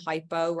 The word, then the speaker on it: hypo: And the way I always remember this is hyper means like high and hypo rhymes with low.